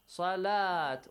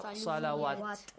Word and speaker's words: salat: It will be pronounced as salat. Wow, on the top of wow, there is dagger alif. Not salawat.